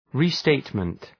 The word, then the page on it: restatement: rı’steıtmənt